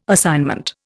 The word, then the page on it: assignment: Indian